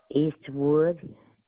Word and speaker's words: Eastwood: Eastwood.